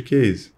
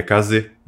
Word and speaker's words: Kaze: Tipo, antes da gente entrar no ar até, tipo, a gente sempre chamou internamente de case. E aí a gente sabe que a pronúncia correta da palavra do japonês, que significa vento, né, se eu não me engano, é kaze.